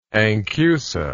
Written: anchusa

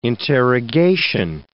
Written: interrogation

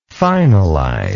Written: finalizer

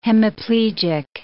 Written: hemiplegic

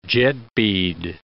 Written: jetbead

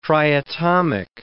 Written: triatomic

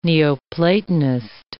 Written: Neoplatonist

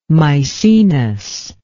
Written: Misenus

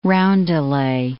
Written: roundelay

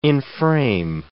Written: enframe

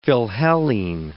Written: philhellene

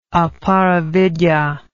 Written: aparavidya

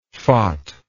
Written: phot

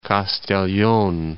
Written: Castellon

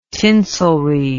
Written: tinselry

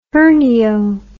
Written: hernio

